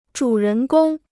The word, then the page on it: 主人公: zhǔ rén gōng